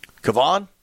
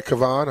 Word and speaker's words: Kevon: How do you say K-E-V-O-N? Kevon? – Yeah, Kevon or Kevon. However, they want to accentuate that, the E.